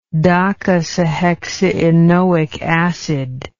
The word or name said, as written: docosahexaenoic acid